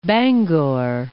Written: Bangor

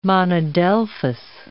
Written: monadelphous

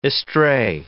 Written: estray

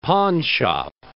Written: pawnshop

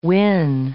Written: win

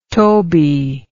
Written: Tobey

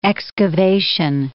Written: excavation

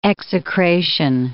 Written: execration

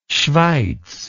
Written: schweiz

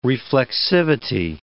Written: reflexivity

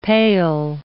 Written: pale

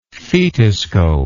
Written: fetoscopist

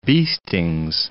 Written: beestings

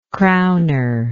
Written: crowner

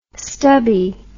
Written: stubbie

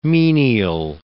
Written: menial

